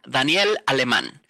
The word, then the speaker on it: Daniel Alemán: So when I am in Mexico, which is where I was born, I say my name as Daniel Alemán, which is the proper Spanish way to say it.